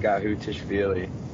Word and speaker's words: Gakhutishvili: Yeah, so it's actually pronounced Gakhutishvili. It's not really that hard once you break it down.